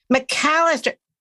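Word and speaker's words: Macalester: McAllister.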